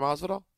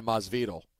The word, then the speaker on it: Masvidal: All right, well, would it help if you heard Chris Carlin mispronounce Jorge Masvidal? – It might. – Let's listen. – Masvidal. – Masvidal. – One more time. – Masvidal. – Yeah, Masvidal.